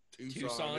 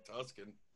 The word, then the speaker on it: Tucson: It's just because if you mispronounce fucking Tucson, it sounds like Tusken.